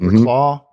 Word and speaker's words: Reklaw: Jesse Reklaws. I'm not sure how you pronounce the name. R-E-K-L-A-W. Reklaw. Reklaw. – I go Reklaw.